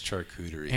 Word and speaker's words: charcuterie: You know, and I like going to my brother's house because they always have a charcuterie board. Is that how you pronounce it? – Yes, it's charcuterie.